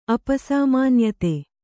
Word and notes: ಅಪಸಾಮಾನ್ಯತೆ: apasāmānyate

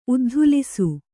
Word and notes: ಉದ್ಧೂಲಿಸು: uddhūlisu

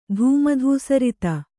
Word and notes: ಧೂಮಧೂಸರಿತ: kannada

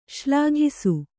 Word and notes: ಶ್ಲಾಘಿಸು: Kannada

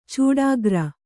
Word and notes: ಚೂಡಾಗ್ರ: Kannada; cūḍāgra

